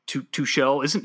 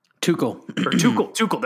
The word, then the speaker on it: Tuchel: And if I'm not mistaken, someone correct me if I'm wrong, but isn't PSG's coach Tuchel, he used to be the Dortmund manager, right? – Tuchel.